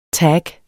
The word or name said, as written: tag